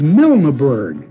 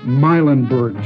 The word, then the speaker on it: Milneburg: But I believe that actually it is Milneburg. And because this song became so popular back in the 20s, everybody somehow just sloughed off the proper pronunciation until it became Mylandburg Joys.